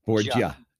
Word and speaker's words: Borgia: I think it's easy, but you pronounced it correctly. The Italian way is Borgia. – Borgia. Yep. But most folks have you coming across as French sometimes, huh? – They stretch out the G, yeah.